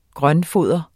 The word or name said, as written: grøntfoder